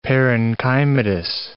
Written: parenchymatous

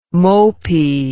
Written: mopy

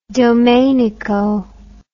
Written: domenico

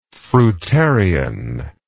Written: fruitarianism

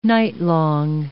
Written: nightlong

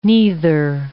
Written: neither

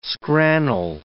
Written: scrannel

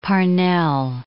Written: Parnell

